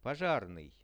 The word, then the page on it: pozharnyj: Russian